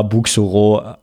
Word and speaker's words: Buxoro: Genau, aber deswegen, ja, Bukhara, Buxoro, also ausgesprochen.